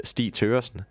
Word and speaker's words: Stig Thøgersen: His name, I'll pronounce it in Danish because I am Danish. I have an advantage here. Is Stig Thøgersen, and he's a professor of Chinese studies at Aarhus University.